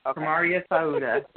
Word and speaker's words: Kamaria Sauda: Kamaria Sauda.